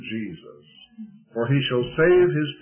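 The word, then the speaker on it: Jesús: They pronounce it Jesus. The J is an H.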